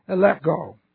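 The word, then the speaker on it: elegcho: This is E-L-E-G-C-H, long O, eleko.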